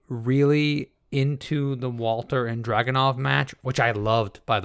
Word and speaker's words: Dragunov: We have Walter versus Dragunov. Is that how you pronounce that? – Yeah, Ilya Dragunov.